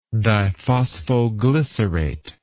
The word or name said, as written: diphosphoglycerate